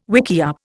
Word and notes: wickiup: Indian

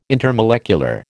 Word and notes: intermolecular: American English

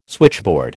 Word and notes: switchboard: American English

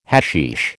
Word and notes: hashish: American English

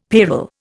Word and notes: payroll: Indian

